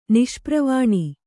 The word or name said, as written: ನಿಷ್ಪ್ರವಾಣಿ